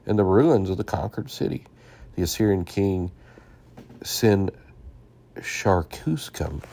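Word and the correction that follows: Sin-shar-ishkun: let me make sure I pronounce that correctly, Sin-Sharishkin,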